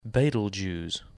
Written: Betelgeuse

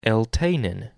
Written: Eltanin

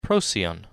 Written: Procyon

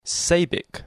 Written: Sabik

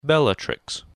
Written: Bellatrix

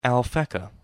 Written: Alphecca

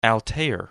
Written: Altair

